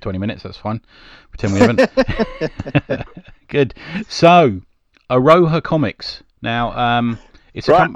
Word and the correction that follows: Aroha: I've said it wrong, have I? – Araha. Araha. – Araha? – Araha. Yeah, that'll okay.